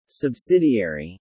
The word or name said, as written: Subsidiary